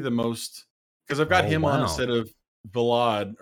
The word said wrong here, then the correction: Velenad: or whatever you pronounce that guy's name, the PL guy. – Velenad, yes.